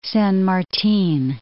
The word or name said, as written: San Martin